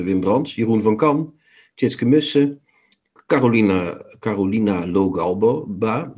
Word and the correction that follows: Logalbo: pardon, Logalba Logalbo.